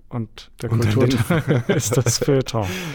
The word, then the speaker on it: Feuilleton: Siehst du, das ist schon ein Wort, wo ich benutze das Wort Feuilleton. Mhm. Habe ich es richtig ausgesprochen? – Das war, glaube ich, richtig.